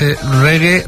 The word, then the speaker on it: reggae: que nosotros la escribimos R-E-G-G-A-E, pero en realidad ellos, la primera vez que lo escribieron, era un poco más como se pronuncia, que es reggae, reggae, o sea, R-E-G-G-A-Y,